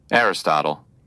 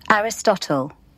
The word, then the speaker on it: Aristotle: Aristotle Aristotle แต่ถ้าเกิดเป็นบริติชจะออกเสียงประมาณนี้ฮะ Aristotle